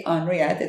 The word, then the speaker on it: Henriette: I mean with my name as well, Henriette. Yeah. It's supposed to be Henriette. It's French. – I mean, the, only the French pronounce it properly, actually.